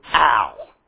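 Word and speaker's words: al (על): The word for is the word, according to the Ashkenazi pronunciation of Hebrew, al.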